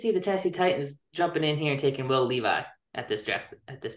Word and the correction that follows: Levis: Will Levis. – That's how you pronounce that name.